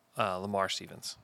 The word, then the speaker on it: Lamar Stevens: LeVar Stevens. I think that's who it was. Lamar Stevens. Oh, my gosh. I've said his name wrong 500 times.